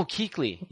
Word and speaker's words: Kuechly: Yeah, we really got to work on your non-Chief pronunciation. – Oh, Kuechly. Yeah, my bad. I only read them. I never hear them.